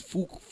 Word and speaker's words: Fuq: Instead of spelling it, you know, F-U-C-K, we spell it F-U-Q. – Yeah. – So he pronounced it fook.